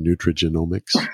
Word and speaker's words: nutrigenomics: nutrigenomics? Did I say that right? – You did.